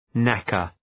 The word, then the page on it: knacker: ‘nækər